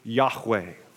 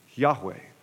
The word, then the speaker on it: Yahweh: We don't even know how it was pronounced. It's been tried to be pronounced in many ways, and we probably have landed on the most common pronunciation of these four letters being Yahweh.